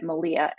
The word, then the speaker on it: Malea: So I took my middle name, which was Marie, and put it with my old last name, Levitt, and made it Malia, M-A-L-E-A, because that's how Levitt is spelled.